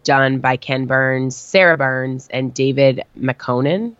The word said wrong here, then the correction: McMahon: Is that how you say his name? – McMahon. – That's how you say that? – Yes.